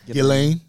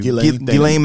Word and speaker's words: Ghislaine: But the Ghislaine or Ghislaine, however you pronounce her name, her case. – Ghislaine? – Okay. I'm sorry. Thank you. – Ghislaine Maxwell.